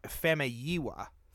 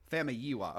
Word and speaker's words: Famuyiwa: I looked it up. It's Rick Famuyiwa. – Famuyiwa, okay.